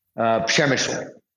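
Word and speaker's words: Przemyśl: Przemysl.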